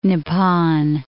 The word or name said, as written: Nippon